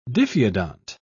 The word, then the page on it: diphyodont: British English